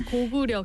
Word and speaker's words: Goguryeo: Dating way back to the Goguryeo kingdom. – Yeah, it's Goguryeo kingdom. – There you go. – You pronounce it right.